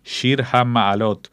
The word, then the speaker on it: Shir HaMa'alot: ahora seguimos con el coro sinagogal de Ámsterdam y con una vieja obra, Shir HaMa'alot, o como se pronuncia en Ámsterdam, Shir HaMangelous.